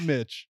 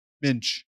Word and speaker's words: Minch: Midge. – Minch. – Minch. – Not Mitch. Minch. – Minch. – Spell that? – M-I-N-C-H.